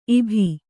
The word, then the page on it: ಇಭಿ: kannada